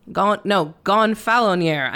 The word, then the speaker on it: Gonfaloniere: The Galfoniere. – The Galfoniere. – Gonfaloniere. I missed another fucking consonant syllable.